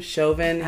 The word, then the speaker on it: Chauvin: Chauvin.